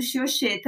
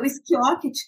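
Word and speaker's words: Schiocchetti: Ó, o Vinícius está falando Xioxê também, muita gente fala Xioxê. Eu aceito todas as pronúncias, é. É, apesar de achar algumas que são mais feias que as outras. É, mas o esquioquete, que é como eu falo agora,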